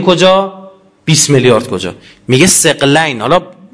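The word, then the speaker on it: ثقلین: دو تا چیز، سقلین، درسته این تلفظش سقلین نه سقلین، سقلین یعنی جنوئنس، سقلین یعنی دو چیز گرانبهای هم وزن.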